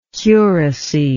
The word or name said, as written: curacy